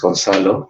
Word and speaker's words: Gonzalo: So, yeah, my name is Gonzalo. That's how you pronounce it in Spanish.